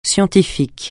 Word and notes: scientifique: francais

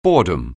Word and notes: boredom: anglais

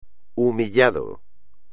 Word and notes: humillado: espagnol

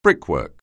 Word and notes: brickwork: anglais

